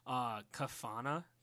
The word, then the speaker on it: Kafana: If you ever get to New York City, go to this awesome ass place called Kafana. Did I say that right? – Yep. Yes.